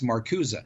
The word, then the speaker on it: Marcuse: So it's spelled Marcuse, but it's pronounced Marcuse.